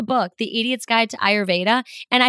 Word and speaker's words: Ayurveda: And it's pronounced Ayurveda. The R is not silent, contrary to my previous belief.